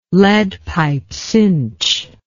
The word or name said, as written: lead pipe cinch